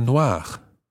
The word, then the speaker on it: noirs: So, un livre noir, deux livres noirs. Sounds the same, but it's spelt this time N-O-I-R-S. Noir. So, un livre noir, deux livres noirs. And the feminine, une voiture noire, N-O-I-R-E.